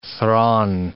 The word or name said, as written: thrawn